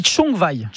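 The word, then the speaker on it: Csongvai: Alors, première chose, ça ne se prononce pas comme ça. – c'est compliqué, parce que ça s'écrit S-O-N-G-V-A-I, mais donc, on pourrait dire... Oui, CS, pardon, en plus. Et ça se dit Chongwai. – Chongwai. – Chongwai, d'accord. – Comme si vous mettiez TCH devant pour commencer le mot.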